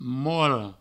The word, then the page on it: mole: mouole